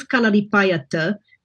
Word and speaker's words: Kalaripayattu: But this one you see here, when it's doubled, is pronounced like a hard T. Mm-hmm. And also, if it's in compound with another consonant, becomes like a D. So it's very confusing. So it is Kalaripayattu. Some people do change it to a T when they transliterate it.